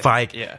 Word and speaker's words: Feige: Is that how you say his name? – Yeah, I think it's Feige. Yeah, Feige.